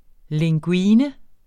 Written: linguine

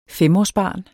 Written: femårsbarn

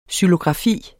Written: xylografi

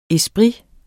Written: ESPRITERNE

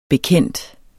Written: bekendt

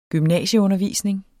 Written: gymnasieundervisning

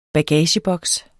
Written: bagageboks

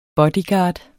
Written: bodyguard

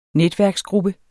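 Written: NETVÆRKER

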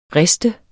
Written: riste

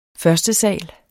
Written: førstesal

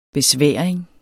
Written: besværing

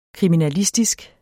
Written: kriminalistisk

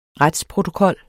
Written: retsprotokol

